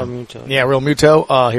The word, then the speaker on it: Realmuto: Realmuto? Is that how you say it? Yeah. Realmuto.